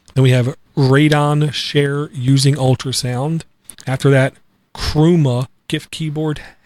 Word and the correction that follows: Chrooma: How do you pronounce that, Eric? – Chroma. – Chroma. C-H-R-O-O-M-A. GIF keyboard. Then we have contextual app folder, C-A-F.